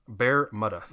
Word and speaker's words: Bermuda: Did I say Bermuda? – It's actually pronounced Bermuda.